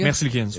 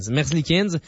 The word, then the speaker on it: Merzlikins: Ils reçoivent aussi le gardien Elvis Merzlikins, c'est-tu comme ça qu'on prononce, les gars? – Merzlikins. – Ouais. Merzlikins.